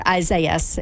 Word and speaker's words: Isaias: Now, the way you're pronouncing that, that's the Spanish version, but the English version of pronunciation is Isaias. Pretty easy. – All right, that is a lot better, Isaias, instead of Isaias.